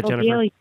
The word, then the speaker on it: lobelia: How do you say that, lobelia. – Lobelia. Thank you.